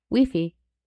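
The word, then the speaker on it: wifi: Yeah, that's how you say it in French. Weefy.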